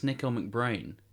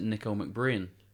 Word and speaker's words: McBrain: I think I've pronounced it wrong all these years. I think it's Nico McBrain. – Brain? – Brain, but I've always pronounced it Nico McBrain.